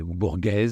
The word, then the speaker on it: Borges: Il a une passion pour Borges, c'est Borges, si on doit prononcer le nom.